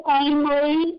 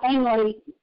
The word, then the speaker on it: Aimoey: It's Amory, A-I-M-O-E-Y, Amory. Most people don't pronounce it right.